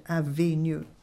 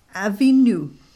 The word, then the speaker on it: Avinu: So Avi, A-V-I, is my Father. Okay. Now, usually when that is written in English, in phonetics, it's A-B-I and A-B-I-N-U, but I'm giving you the correct pronunciation, which is V. Okay, so that's why I said Avinu. So I said Avinu.